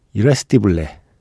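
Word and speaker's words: Irresistible: 이거는 스페니쉬 발음이 맞는 것 같아요. 이레스티블레, 예, 위신, 이얀데레 노래로 시작해서요.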